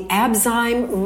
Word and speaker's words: Abzyme: We are joined today by Zachary Barnett, who is the founder of the Abzyme Research Foundation, correct? – Thank you for pronouncing that correctly.